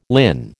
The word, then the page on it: linn: American English